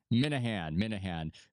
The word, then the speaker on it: Minihane: Is that the way to pronounce it properly? – Minahan. – It's very Irish, I think, which is that you were more certain.